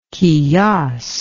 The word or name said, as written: qiyas